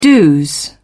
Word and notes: dos: British English

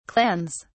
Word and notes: clans: British English

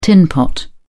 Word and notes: tinpot: British English

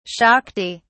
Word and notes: shakti: British English

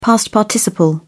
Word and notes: past participle: British English

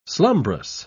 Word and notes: slumbrous: British English

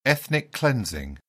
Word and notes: ethnic cleansing: British English

